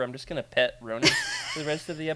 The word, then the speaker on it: Ronin: How do you say it, Freddie? – Ronin. – Yeah, he says it so much better. I actually, ever since you said that, have only heard it in your voice.